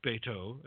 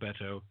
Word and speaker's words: Beto: It seems the former Texas Senate candidate, Mr. Beto is actually the correct Hispanic pronunciation, wants to be called Beto, is actually Robert Francis O'Rourke.